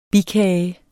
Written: bikage

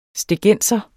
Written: stegenser

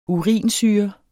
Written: URINSYRE

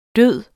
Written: død

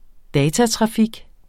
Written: datatrafik

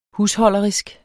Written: husholderisk

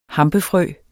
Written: hampefrø

